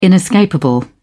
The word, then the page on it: inescapable: British English